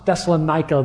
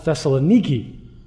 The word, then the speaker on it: Thessaloniki: And just a quick disclaimer, they don't pronounce Thessalonica the way we do. They call it Thessaloniki.